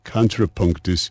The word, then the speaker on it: Kontrapunktus: Go to Contrapunktus, which is spelled KONTRAPUNKTUS, Contrapunktus.